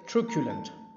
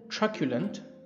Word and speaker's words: truculent: Today's word is truculent by British pronunciation and truculent by American pronunciation. Truculent or truculent is spelled as T-R-U-C-U-L-E-N-T. For the sake of convenience, I will stick to the British pronunciation.